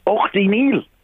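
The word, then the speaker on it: Ughtyneill: Ochty Neil.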